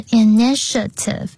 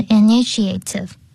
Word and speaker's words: initiative: 然后呢 ,initiative 的发音很重要，是 initiative，不是 initiative， OK？